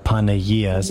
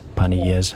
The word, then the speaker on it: Panagias: Or if you go to Facebook, yes, and you look up Panagias, mm-hmm, that's spelled Panagia, apostrophe S, Panagias, yes, Ministry.